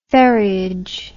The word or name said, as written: ferryage